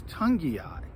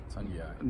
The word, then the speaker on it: Togiai: but it's gotten to the point where I learned how to say his name, and he's been around for a while. It's Togiai. Togiai. Noah Togiai.